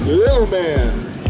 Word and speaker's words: Lil Man: You don't even know how to pronounce his name correctly. It's Lil Man. Lil Man, L-I-L.